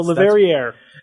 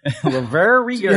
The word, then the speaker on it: Le Verrier: Why the hell stick an R on the end of your word if you don't want it pronounced? – So, Le Verrier. – Le Verrier.